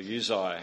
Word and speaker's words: Uzziah: Amos the prophet was in the days of Uzziah, king of Judah, or Uzziah, however you like to pronounce it, which was the southern kingdom, and Jeroboam, the son of Joash, the king of Israel, which was the northern kingdom.